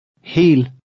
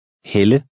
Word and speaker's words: hel: The first word makes a long E sound, and the second, a woman's name, makes the Danish short E sound. Listen and repeat number 10. – Hel. Helle.